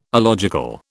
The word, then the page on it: alogical: British English